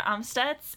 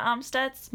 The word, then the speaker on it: Amstutz: I'm Sandra Amstutz, and you can find me on all social platforms at Sandra Amstutz.